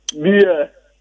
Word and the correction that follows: bear: But then the animal is pronounced bear.